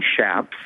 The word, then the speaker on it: Shaps: Well, first of all, my name is Stephen Schaps, and my last name is spelled S-H-A-P-S.